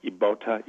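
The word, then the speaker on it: Ybota: is a variety I found in Africa called Ibota Ibota. That's spelled Y-B-O-T-A, Y-B-O-T-A, and it was in the Congo, and it is a what they call an apple banana.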